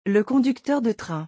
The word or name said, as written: le conducteur de train